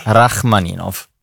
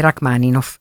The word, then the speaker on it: Rachmaninov: E Duo Gardel, musiche di Brahms, Piazzolla, Gardel, Ubay, Galliano e Rachmaninov. – Ok, ti aggiusto un po' le pronunce dei compositori. È Galliano, Gardel e Rachmaninov.